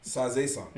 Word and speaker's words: Sazae-san: How you say it? Sazu-san. – Sazae-san. – Yeah, Sazae-san.